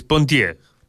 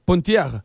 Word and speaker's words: Pontier: Sí, igual lo está pronunciando mal, es Pontier. – Pontier.